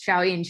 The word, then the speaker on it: Xiaoyin: I guess my name is X-I-A-O-Y-I-N is my first name and Q-U is my last name. It's really hard to pronounce, but it really pronounces Xiaoyun Chu.